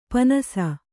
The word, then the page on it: ಪಣ್ಣಿಸು: Kannada